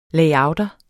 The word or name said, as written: layouter